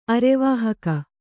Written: ಅರೆವಾಹಕ

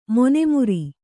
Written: ಮೀನುಮರ

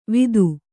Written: ವಿತ್ತು

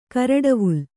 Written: ಕರಡವುಲ್

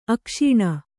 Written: ಅಕ್ಷೂಣ

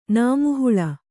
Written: ನಾಮುಹುಳ

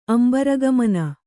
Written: ಅಂಬರಗಮನ

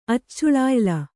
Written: ಅಚ್ಚುಳಾಯಿಲ

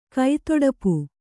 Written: ಕೈತೊಡಪು

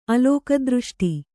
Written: ಅಲೋಕದೃಷ್ಟಿ

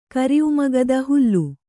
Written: ಹೋಲು

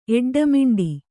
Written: ಎಡ್ಡಮಿಂಡಿ

